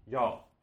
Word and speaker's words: jag: But it's in speech and spoken form, it's absolutely okay to drop that G and just say ja. For example, jag heter Nils, instead of jag heter Nils. But at the same time, I just want to say that if you prefer to pronounce the G, that's never going to be wrong. It's just that in common speech, you find that people do drop the G more often than not.